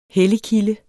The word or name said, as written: helligkilde